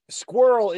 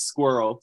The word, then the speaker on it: squirrel: Yeah, well, how you say it is squirrel. Squirrel. It's kind of like squirrel, like one squirrel.